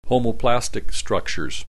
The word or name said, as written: homoplastic structures